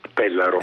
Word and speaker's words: Pellaro: Allora, noi volevamo ricordare ancora il vostro centro ACE di Pellaro, è giusto l'accento? – Pellaro. – Esatto. Pellaro, Pellaro sempre, sbaglio sempre.